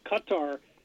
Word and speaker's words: Qatar: They were talking about Qatar, the country in the Persian Gulf, being pronounced, it should be pronounced more like cupper or gutter.